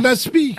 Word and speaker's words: aspic: Non, un aspi. – Ah oui, alors on ne prononce pas le C qui est à la fin. Un aspi, ou aspic si vous prononcez le C.